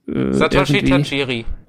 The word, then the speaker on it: Satoshi Tajiri: Ist jemand von euch des Japanischen mächtig, bevor ich mich hier mit der Aussprache der Namen Satoshi Tajiri. – Okay, genau, so hätte ich es jetzt auch gesagt. Genau, Satoshi Tajiri hat diese Idee gehabt und dann mit Game Freak, mit dem Studio zusammen angefangen, diese Pokémon-Spiele zu entwickeln.